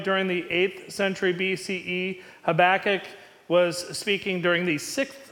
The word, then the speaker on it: Habakkuk: Habakkuk, I asked him how he wanted his name pronounced, and that's how he said, so that's what I'm going with.